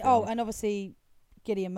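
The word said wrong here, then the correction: Cillian: Murphy was... – Killian Murphy. – Is it Killian? – You get that name wrong every time.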